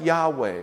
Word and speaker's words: Yahweh: So we think it would be pronounced something like Yahweh or Yahweh.